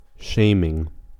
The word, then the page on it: shaming: American English